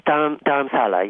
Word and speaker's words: Dharamsala: And Dharamsala, is that how you pronounce it? – Yes, Dharamsala, yes. – Dharamsala.